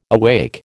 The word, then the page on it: awake: American English